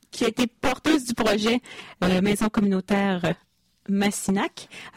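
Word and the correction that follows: Missinak: Alors merci, je l'ai bien prononcé? – Missinac.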